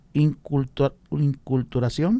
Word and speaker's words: inculturación: A ver si lo digo bien, la inculturación.